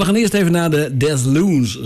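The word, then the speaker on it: Deslondes: Zo spreek je het uit. Ik heb het even opgezocht. Ik sta altijd te zoeken van hoe spreek je dat nou in vredesnaam uit? Desloons, groeit het op de Franse toer? Nee, Desloons.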